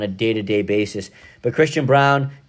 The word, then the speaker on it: Braun: Christian Brown, to me, is the X factor on Kansas. Yeah, it's spelled Braun. It's B-R-A-U-N, but they call him Brown.